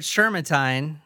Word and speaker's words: Shermantine: Wesley Howard Shermantine.